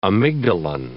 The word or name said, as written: amygdalin